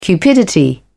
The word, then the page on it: cupidity: British English